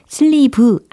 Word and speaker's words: sleeve: 옷 소매, 예, 이걸 떠올리시면 S, L, E, E, V, E. 그쵸? 자, 발음 여러 가지로 하고 계시는데, sleeve 아니고 sleeve, sleeve, sleeve. 장음 처리되고 있습니다. 그죠? sleeve 이게 아니고 sleeve, sleeve라고 해주셔야 되겠죠.